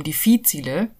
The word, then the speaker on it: difficile: Clostridium difficile oder Clostridium difficile, wie es eigentlich ausgesprochen wird, ist veraltet.